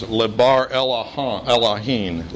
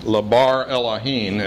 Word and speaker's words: Lebar Elohim: Up here on the board, that phrase written in Aramaic is Lebar Elohim, Lebar Elohim, and if you read it from right to left, you'll see how I'm getting that pronunciation. Lebar Elohim.